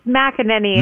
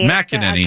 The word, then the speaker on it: McEnany: We are joined now by RNC National Spokesperson Kaylee McKenney. Am I pronouncing your last name correctly? – It's McEnany. – McEnany.